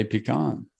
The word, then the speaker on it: pecan: How do you pronounce this word? – I say pecan, but Okay.